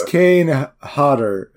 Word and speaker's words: Kane Hodder: Did we say it was Kane Hodor? – Yeah. That's not his name. I did not get his name right, I guess. – That's not his name. It's Kane Hodder.